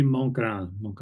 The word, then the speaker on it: Montgrand: Am I pronouncing your name right? – Very close, yeah. Joel de Montgrand. – Yeah, like Mont Blanc.